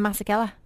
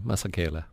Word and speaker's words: Masekela: I was calling him Masekela. – No, Masekela.